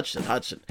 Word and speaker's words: Hodgson: So the thing about Hayden Hodgson, how do you say that name? Hayden Hodgson. Hodgson. Like Dodson from Jurassic Park.